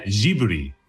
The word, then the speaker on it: Ghibli: No, ahora lo has dicho peor, pero al principio lo has dicho perfecto, que es, en realidad se pronuncia Ghibli, ¿vale? Que es como teóricamente lo dicen los japoneses y deberíamos decirlo, ¿no?